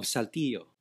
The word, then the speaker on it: Saltillo: Did I say it right? – No, but you can go with it. How do I say it? How do you say it? – Roberto Esquivel Cabrera of Saltillo.